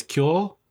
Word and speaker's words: Kuiil: It's pronounced, it's written here as K-U-I-I-L. So I want to say it's Kuil.